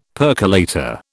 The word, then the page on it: percolator: British English